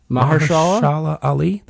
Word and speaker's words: Mahershala Ali: Mahershala Ali? The guy who's Mahershala Ali, yeah, yeah.